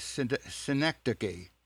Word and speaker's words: synecdoche: I think I'm going to pronounce the word correctly, synecdoche. – It's connected. – That hat that he's talking about is somewhat of a synecdoche.